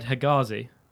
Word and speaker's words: Hegazi: This is Ahmed Hegazi, is how I'd pronounce it.